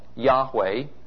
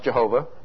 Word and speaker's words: Yahweh: We notice that in the prologue and epilogue, the prose portions of the book, that the covenant name of Israel's God, Yahweh, mispronounced Jehovah, mentioned before,